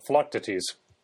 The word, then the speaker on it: Philoctetes: Wiki's Greek pronunciation goes with stress the third syllable, so Philoctetes.